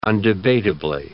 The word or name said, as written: undebatably